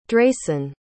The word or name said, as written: drayson